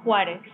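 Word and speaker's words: Juárez: How should I say it? – Juarez. – Juarez. – There you go.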